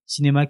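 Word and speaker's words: Scinéma: Je vous renvoie vers la chaîne qui s'appelle Cinéma. Cinéma qui s'écrit S-C-I Néma.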